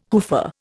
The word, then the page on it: hoofer: Indian